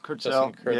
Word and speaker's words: Justin Kurzel: Justin Kurzel, yeah.